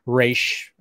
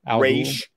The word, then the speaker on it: Ra's: Like pretty much just Ra's. – Ra's. Oh, yeah. I pronounce it like it's spelled R-A-Y-S-H. – Okay. Ra's. Yeah. Ra's. Kind of like this, I guess you could say.